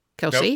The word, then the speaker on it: Kelce: Kelce? – Nope, it's C. Kelce? It's a soft C. – Okay. Yes. Thank you. – Or hard C, I'm sorry.